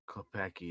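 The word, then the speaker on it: Klepacki: All the music's been redone by Frank Klepecki. I think that's how you say his name. – Klepecki, yeah.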